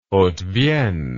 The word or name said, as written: haute vienne